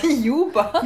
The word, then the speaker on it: Juber: Also entweder ihr schreibt uns eine E-Mail unter blog at, wird es Jabba ausgesprochen oder Juba? – Juba.